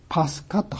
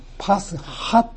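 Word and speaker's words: パ ス ハ (Πάσχα): ギリシャ語ではこの杉越というのはパスカ、まあ、古典の読み方では、私が神学校で習った古典の読み方ではパスカと発音しますが、まあ、現代のギリシャ語の読み方ではパスハと言います。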